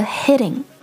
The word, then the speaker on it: hitting: 者是警察 police 的时候我们说的一定是 someone is hitting me 而不是 hitting on me 这一点千万要注意还有我们今天提到的其他的几个单词 heading towards heading H-E-A-D-I-N-G heading 朝某个方向而去和 heating H-E-A-T-I-N-G heating 暖气 heading, heating 和 hitting, H-I-T-T-I-N-G, heading, heating, hitting. 这三个单词要把它区分开来的话元音发音是至关重要的也是唯一一个从听力上能够把这三个单词区分开来的一个办法。所以标准的元音发音相当重要。